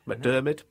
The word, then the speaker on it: McDiarmid: McDiarmid.